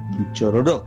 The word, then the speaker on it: Quichororó: El registro es Quichororó, con un acento en la última O. Quichororó.